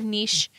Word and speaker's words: niche: Is it niche or niche? I pronounce it niche. – Niche? – Because it rhymes with rich.